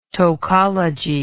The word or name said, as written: tocology